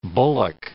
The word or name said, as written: bullock